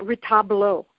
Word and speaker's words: retablo: So retablo, am I saying that right? – Yeah, retablo. – Retablo. Okay.